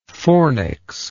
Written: fornical

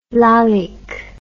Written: lalique

